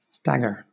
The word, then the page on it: stagger: British English